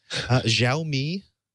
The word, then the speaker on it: Xiaomi: Xiaomi, the Mi Pad, is that the right name for that? Tegra K1 powered? – I think that is correct. I think you got that pronunciation correct.